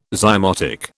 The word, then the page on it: zymotic: British English